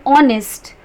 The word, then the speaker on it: honest: We use an before the words are and honest. When we pronounce these two words, h is silent.